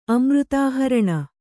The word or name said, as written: ಅಮೃತಾಹರಣ